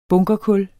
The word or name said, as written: bunkerkul